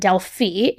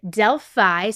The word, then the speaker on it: Delphi: But so you know, also in one of my very first episodes when I ever covered this case, I completely mispronounced Delphi, Indiana, and I called it Delphi, Indiana. So you might hear that a little bit too. It is Delphi.